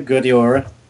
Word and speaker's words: Guedioura: I can't pronounce it now, but is it Ad- Adlène Guedioura? Yeah, Guadiara.